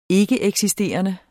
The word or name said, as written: ikkeeksisterende